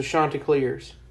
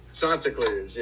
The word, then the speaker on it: Chanticleers: How do you say? – The Chanticleers. – I believe it's the Chanticleers. – Chanticleers, yeah. What up, they get mad. For some reason, they get mad if you mispronounce that.